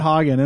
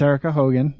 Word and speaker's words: Hogan: I almost said after we talked about it, I almost said Hagen, and that's not even how I wanted to pronounce it initially. But it's Erica Hogan, from the Land of Salt.